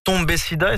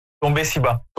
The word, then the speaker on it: Tomber si bas: Alors, Tombé Sida, est-ce que je le prononce bien? – Tomber si bas.